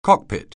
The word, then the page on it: cockpit: anglais